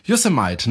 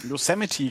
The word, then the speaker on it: Yosemite: Yosemite, ne? Habe ich das richtig gesagt? – Yosemite, dachte ich. – Oh, ja, ja, ja. Das ist schon wieder falsch gemacht. Yosemite.